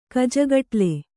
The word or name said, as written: ಕಜಗಟ್ಲೆ